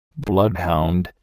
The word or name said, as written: bloodhound